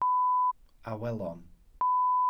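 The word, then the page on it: awelon: Welsh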